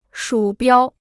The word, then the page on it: 鼠标: shǔ biāo